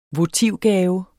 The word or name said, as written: votivgave